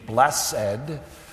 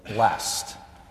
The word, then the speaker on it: blessed: use the two-syllable pronunciation, blessed, but rather more often than not, it comes out quickly and less formally as just a single blessed.